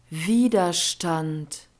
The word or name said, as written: widerstand